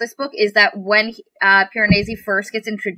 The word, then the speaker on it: Piranesi: And I just really, really, really like how genuine and innocent Piranesi, whatever. – It's Piranesi. – No. – That's how it's pronounced. Piranesi.